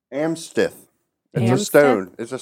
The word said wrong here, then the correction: amethyst: Oh, amethyst.